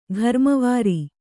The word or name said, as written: ಘರ್ಮವಾರಿ